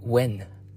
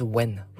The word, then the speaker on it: Nguyen: David Nguyen, but they say it when. They say it as when. What do you think? It's spelled as Nguyen. And when I went to Vietnam, it says it is pronounced as Nguyen.